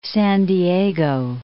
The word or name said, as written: San Diego